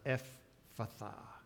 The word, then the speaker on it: Ephphatha: In Greek, when there are two consonants that come together, you're supposed to pronounce both of them. So that's why I call that Ephphatha. Pronouncing both of those PH sounds.